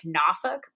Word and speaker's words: Norfolk: And for those of you who are natives of Norfolk, I did not pronounce it correctly, and I know that. It's more like Norfolk, but I'm not going to do that.